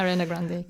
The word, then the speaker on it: Ariana Grande: Schreibst du eben, weil du Ariana Grande, glaube ich, jetzt genannt hast, habe ich es richtig ausgesprochen? – Ja, Ariana Grande, genau.